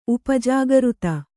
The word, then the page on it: ಉಪಜಾಗೃತ: upa jagřta